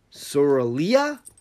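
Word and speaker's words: Sorilea: The audiobooks pronounce it Sorrelia, but I have always pronounced it Cerulea.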